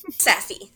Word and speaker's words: SAFY: Is it SAFY? How does it go by? – Safi. – Oh, Safi.